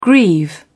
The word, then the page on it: grieve: British English